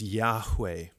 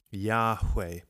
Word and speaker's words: Yahweh: But so the traditional and most likely way that this name was pronounced by ancient Israelites is Yahweh, Yahweh.